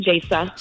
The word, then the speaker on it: Jasa: It's actually Jasa. – Sorry, Jasa. My fault. – How dare you? – I gave you the short E.